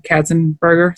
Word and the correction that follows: Ratzenberger: Is that how you say his name? – Ratzenberger. – Did I say Katzenberger?